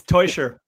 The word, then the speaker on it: Tuchel: It's Tuchel. I know it's a bit confusing for English speakers. It's a Swiss family name. And yeah, it's spelled Tuchel as if it was spelled T-O-Y.